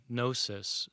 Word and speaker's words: gnosis: The term Gnostic comes from a Greek word, gnosis, spelled with a G, G-N-O-S-I-S, gnosis, which means knowledge.